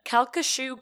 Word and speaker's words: Calcasieu: So this is the Calcasieu Courthouse in the city of Lake Charles. – Calcasieu? – Calcasieu.